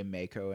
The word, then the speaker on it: Mako: I'm going to keep saying Mako for the rest of my life now, just because of that. You know what would be the test is Advent Children. They probably say Mako and Advent Children.